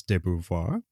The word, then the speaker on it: de Beauvoir: I read on the incredibly well-sourced and respected Internet Encyclopedia of Philosophy that Americans and Brits pronounce de Beauvoir differently if you're just referring to her last name. Apparently, the UK prefers de Beauvoir, whereas the Americans just say Beauvoir.